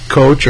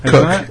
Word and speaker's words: Koch: You say Coach or Koch or... It's Greg Koch is the way I pronounce it. – Yeah, I would as well, and I've heard him... – Cook. – It yeah, is I think cook. It is Cook, but I've heard it him say cook.